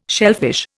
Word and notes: shellfish: Indian

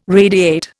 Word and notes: radiate: Indian

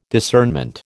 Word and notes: discernment: American English

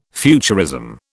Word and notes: futurism: British English

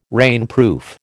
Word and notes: rainproof: American English

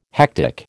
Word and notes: hectic: American English

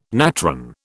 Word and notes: natron: British English

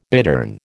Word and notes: bittern: American English

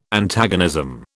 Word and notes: Antagonism: British English

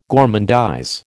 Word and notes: gormandize: American English

